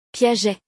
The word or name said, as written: Piaget